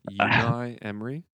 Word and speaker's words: Unai Emery: Unai Emery.